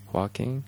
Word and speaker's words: Joaquin: Joaquin Phoenix? – Right.